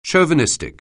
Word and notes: chauvinistic: anglais

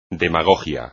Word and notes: demagogia: espagnol

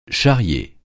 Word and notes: charrier: français

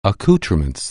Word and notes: accouterments: anglais

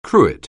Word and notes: cruet: anglais

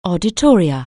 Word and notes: auditorium: anglais

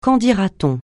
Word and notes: qu'en-dira-t-on: francais

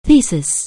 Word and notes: thesis: anglais

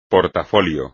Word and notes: portafolio: espagnol

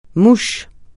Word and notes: mouche: francais

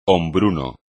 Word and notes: hombruno: espagnol

